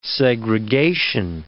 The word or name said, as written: segregation